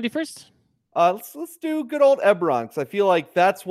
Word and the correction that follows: Eberron: It is pronounced Eberron.